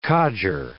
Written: codger

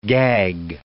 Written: gag

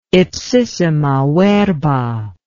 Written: ipsissima verba

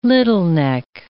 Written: littleneck

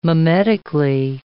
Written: mimetically

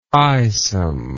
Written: eyesome

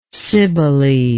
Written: cybele

